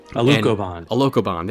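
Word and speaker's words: Alucobond: And it's supported by, and about 3 feet off of the structural domes, is the cladding sphere, which is a shiny Alcoban panels, I think it's called. I don't know, I'm saying it wrong. – A locobond, there we go.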